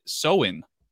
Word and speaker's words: Samhain: This one, if you want to be accurate, you pronounce it Samhain. Samhain is the way to pronounce this. Samhain is how they pronounce it in the show.